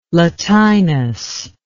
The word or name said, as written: latinus